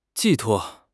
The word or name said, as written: 寄托